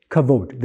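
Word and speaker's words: kavod (כבוד): The word glory, say kavod. Kavod. That's how you pronounce it in Hebrew, kavod.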